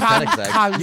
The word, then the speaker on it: Koenigsegg: You could easily get a Koenigsegg.